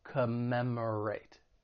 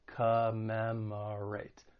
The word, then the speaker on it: commemorate: When pronouncing this verb, it has 4 syllables. The stress is on that second syllable. Commemorate. Commemorate. And just keep in mind that first and third syllable have that schwa sound, that unstressed vowel sound, which sounds like a.